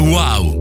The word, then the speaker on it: UAU: Si scrive UAU, si legge wow.